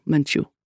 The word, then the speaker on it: Menchu: "I am Father Arturo Menchu," accent on the second syllable.